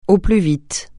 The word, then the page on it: au plus vite: francais